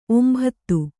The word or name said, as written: ಒಂಬತು